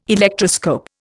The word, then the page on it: Electroscope: Indian